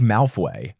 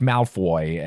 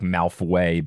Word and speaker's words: Malfoy: So I think that's what it was, is I think I heard British people saying like Malfoy and thought yeah. – it was like Malfway, but instead of saying way, they said like woy.